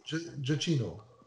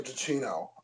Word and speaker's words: Giacchino: Michael Giacchino. – Michael Giacchino.